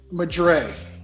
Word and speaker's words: Madry: Madre. – Madre, okay. Oh, I'm all wrong. I was trying to say it like I was speaking French or something, but okay.